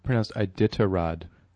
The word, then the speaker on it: Iditarod: She supports and promotes the brutal Iditarod dog sled races. – Oh, I think it's pronounced Iditarod. – No, it's Iditarod.